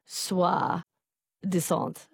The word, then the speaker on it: soi-disant: Soi, it was meant to be soi-disant. Okay, thank you, Fred. Pronunciation correction.